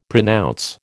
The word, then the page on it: pronounce: American English